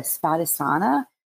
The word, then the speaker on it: Svadhisthana: So it would be the Svadhisthana is how you say it.